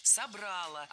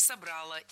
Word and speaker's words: собрало: В отличие от язычества, от того, что было до христианства, именно христианство собрало наш народ воедино. – Собрало, неверно. А правильно, как вы, наверное, уже догадались, собрало. Опять же, убираем ударение с окончания, однозначно. Только собрало и никак иначе.